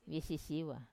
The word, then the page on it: visisiwa: Wamesa